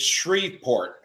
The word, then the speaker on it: Shreveport: And then there's another one from, I think it's pronounced Shreveport. You guys correct me if I mispronounce that. Shreveport RFC, which is, again, selling a shirt to help raise money. And it also some very good kit. I'm sorry, say again? – It is Shreveport, and Drew Tomczak, who is their who set all that up, is the D2 conference representative for Red River.